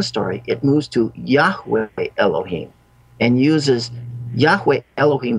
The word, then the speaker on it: Yahweh: Now, this is pronounced in Hebrew, Yahweh. Some people have pronounced it Jehovah.